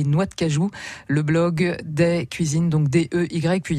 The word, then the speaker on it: Dey: Day, ça s'écrit D-E-Y, hein, c'est Audrey, d'accord. Donc Day.